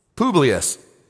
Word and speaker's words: Publius: And it happened that the father of Publius, how do you say it? Publius. Thank you.